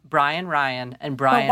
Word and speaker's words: Rein: Keith pronounces it like Ryan. – He does. – Or Ryan. – Ryan. – Ryan, kind Ryan. – Of. – Ryan. – So, like rind without a D. – So it sounds like Brian Ryan the entire episode. – Yeah. But it's spelled R-E-I-N, like rain.